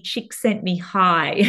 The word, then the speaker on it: Csikszentmihalyi: which is Professor Mihaly Csikszentmihalyi. And I've practiced that a lot of times. We actually unfortunately lost Professor Csikszentmihalyi only a couple of years ago now,